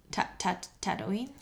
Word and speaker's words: Tatooine: Tatooine? – Yes.